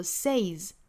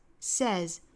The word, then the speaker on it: says: God, 美音的上下开口读更大更松 God 后面的这个 says 大家注意不要读成了 says 一定是注意里面这个元音哈，是单元音不是双元音是 says 后面这个 s 应该是读浊音的清清浊浊的规律哈，元音属于浊音类所以是 z says God says we need to love our enemies need 尾部的的我们要省掉 We need to love our enemies.